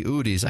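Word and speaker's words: Hudes: It's H-U-D-E-S, and I'm pretty sure the H is silent, so it would be Udes, I believe, if you're saying it in Spanish.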